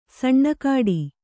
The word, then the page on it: ಸಣ್ಣಕಾಡಿ: saṇṇa kāḍi